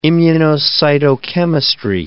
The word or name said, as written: immunocytochemistry